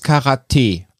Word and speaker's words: Karate: wie es Richtig ist es Karate. Okay. Die Betonung auf die letzte Silbe.